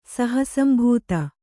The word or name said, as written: ಸಹಸಂಭೂತ